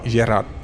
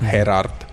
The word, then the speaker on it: Gerard: and I'd obviously misheard because it's Gerard Piqué, not Gerard.